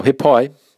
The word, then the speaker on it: he pai: Now, be careful with your pronunciation of this of the word he pai. So, he is a real short saying. A lot of times you'll hear people say here, like